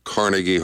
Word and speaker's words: Carnegie: Now you're saying it wrong. It's Carnegie. Carnegie Hall.